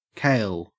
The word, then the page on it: kale: Australian English